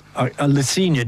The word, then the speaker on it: Le Cygne: How do you pronounce this? Le Cygne? – Le Cygne, the swan. – Yes. – In Francais.